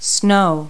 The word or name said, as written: snow